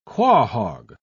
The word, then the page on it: quahaug: British English